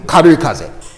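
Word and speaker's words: 軽い風: How do you say karui kaze? karui kaze. Okay, we have this word.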